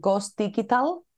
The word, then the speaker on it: Ghostigital: I have observed my Icelandic friends how they pronounce it, Ghost Digital. – Okay. – With a digital.